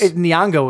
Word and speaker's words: Nyong'o: Nyong'o was her last name. I know oh, yeah. – That. Yeah. – Lupita. – Lupita. – Lupita Nyong'o.